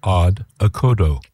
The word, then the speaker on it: Odd Okodo: And they, in fact, are called Odd Okodo. I'll spell that for you. Odd is simply O-double-D, and then O-K-O-double-D-O. Odd Okodo. It's a duo consisting of German electronic experimental drummer Sven Kasirek. That's K-A-C-I-R-E-K, Sven Kasirek, and singer-songwriter and instrument maker Olith Rategó, R-A-T-E-G-O.